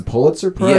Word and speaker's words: Pulitzer Prize: Didn't Kendrick Lamar win the, I want to, it's pronounced the Pulitzer Prize.